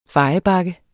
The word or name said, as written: fejebakke